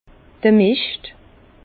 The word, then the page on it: d'r Mischt: Bas Rhin; Herrlisheim